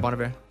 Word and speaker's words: Bon Iver: Yeah, Bon Iver.